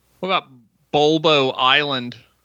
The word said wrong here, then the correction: Boblo: Bulblo. You said it wrong. Boblo. – Boblo? – Boblo. The B becomes before the L. Boblo. B-O-B-L-O. Boblo Island. – Boblo. – Boblo. Boblo Island Amusement Park. On Bois Blanc Island. B-O-I-S-B-L-A-N-C.